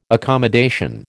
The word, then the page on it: accommodation: American English